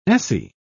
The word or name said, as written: esse